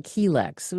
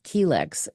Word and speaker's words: Chelex: Well, we just call it Chelex. We just pronounce the C. We don't pronounce the CH as a ch. We just pronounce it as a, you know, as a Chelex, as kind of like chelation. And so that's why, even though it's spelled CHELEX, it's not pronounced that way.